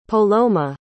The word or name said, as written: poloma